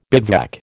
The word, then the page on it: bivouac: American English